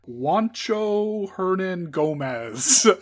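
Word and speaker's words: Juancho Hernangómez: Juancho Hernan Gomez.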